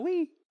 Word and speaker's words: Wii: It's about Wii. I think that you have to say it like that.